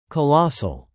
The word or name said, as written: colossal